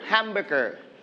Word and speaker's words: hamburger: Hamburger. – Hamburger. – Hamburger. – Hamburger. – We are going to stress on the first syllable, ham.